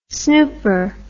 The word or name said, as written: snooper